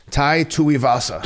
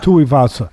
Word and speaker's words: Tuivasa: That happened in it in this fight with Tavasa. Uh, uh, Tavasa. How am I pronouncing? Tui tu, Tai Tuivasa. – Tuivasa,